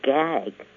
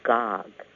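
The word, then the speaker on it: Gág: Most people think her last name is Gag because it is spelled G-A-G, but the correct way to pronounce it is Gog, as though it had an O and not an A for the middle letter.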